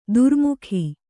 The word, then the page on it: ಧಾರ್ಮಿಕ: Kannada